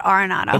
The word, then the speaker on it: Arenado: Randy asked Nolan Arenado, how do you say it, Arenado or Arenado? And he said Arenado.